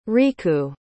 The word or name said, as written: riku